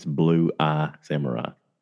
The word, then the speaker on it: Blue-Eyed Samurai: I've been saying it wrong. I've been pronouncing it past tense. With like blue-eyed. It's Blue-Eyed Samurai.